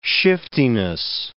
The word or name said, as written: shiftiness